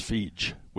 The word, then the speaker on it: Feige: Well, it's pronounced Feige with a J. I looked it up in the interviews and everything. It's Feige.